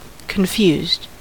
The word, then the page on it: confused: American English